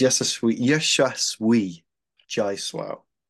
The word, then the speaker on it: Yashasvi Jaiswal: No, it's Yashasvi Jaiswal. – Perfect. – Yeah, sorry, I butchered that a little bit.